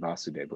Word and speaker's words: Vasudev: And Vasudev with a short A.